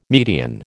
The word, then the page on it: median: American English